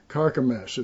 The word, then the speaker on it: Carchemish: and Carchemish is what it is. That's how they pronounce it.